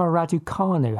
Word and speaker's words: Raducanu: And in terms of creating stories, Emma Raducanu, have I pronounced that correctly? – Ah, yes.